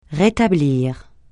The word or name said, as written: rétablir